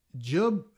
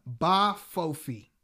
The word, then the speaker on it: Jbafofi: Jub. Oh, boy. Oh. The J is silent. Bafofi. – Bafofi. – Yes. J-B-A-F-O-F-I.